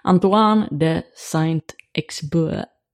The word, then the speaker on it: Antoine de Saint-Exupéry: Antoine de Saint-Exupéry. Eller någonting, jag vet inte. – Perfekt. – Ja, perfekt franska.